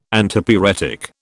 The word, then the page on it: Antipyretic: British English